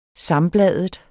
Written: sambladet

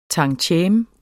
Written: tantieme

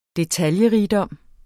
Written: detaljerigdom

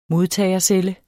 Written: modtagercelle

